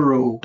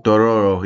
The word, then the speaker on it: Dororo: how you pronounce it? – Doroaro, I think it's called. Do Dodora? – Dodoro. Dodoro. Doroaro. That's it. Yeah. Dodoro. – Dororo. – Dororo. Yeah. – It was like Dororo.